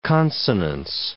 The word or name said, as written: consonance